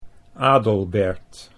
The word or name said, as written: Adelbert